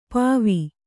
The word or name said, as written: ಪೂವು